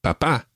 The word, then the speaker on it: papá: And papá. – Papá. – Now, just one other wee thing, we mentioned accents already. Mama and papa both have accents on the last syllable.